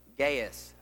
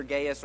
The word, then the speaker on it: Gaius: This particular letter was written to an individual named Gaius. The pronunciation is either Gaius or Gaius. Both of those are correct.